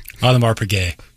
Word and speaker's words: Audemars Piguet: Audemars Piguet. Paget. I'm sorry. Alomar's Paget. – Thank you for the pronunciation correction.